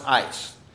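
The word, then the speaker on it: ice (εἰς): But Mike, he says the Greek word in Acts 2, verse 38, 4, is the Greek word ace or ice, depending on your Greek professor. And he points to the Bible, he said it's pronounced ice.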